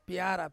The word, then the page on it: piara: Ansus